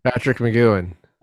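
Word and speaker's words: Patrick McGoohan: I looked up how to pronounce Patrick McGowan. – How do you do that properly? Are we doing it all wrong? – Patrick McGowan. – Oh, good. Okay.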